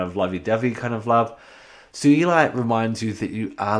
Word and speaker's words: sugilite: Sometimes pronounced sugarlite, but it's sugilite. It was discovered by a Japanese petrologist called Kenichi Sugi.